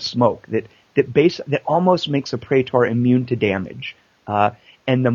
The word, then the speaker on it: praetor: For the listeners, you use manuscripts to train your praetors in new skills.